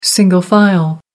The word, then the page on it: single file: American English